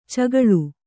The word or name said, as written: ಚಕ್ಕಳೆ